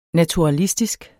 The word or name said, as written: naturalistisk